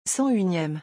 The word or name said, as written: 101eme